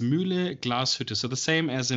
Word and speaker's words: Mühle: And what about Muley? – Mühle. – Mühle. – Mühle. Okay. Mühle Glashütte.